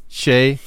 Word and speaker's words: Che: Alltså som Che, alltså S-H-A-Y. Che Adams. – Che. Ja, okej. Okay. Han har annars ett väldigt skönt namn. Che... Shay Zach Everton Fred Adams.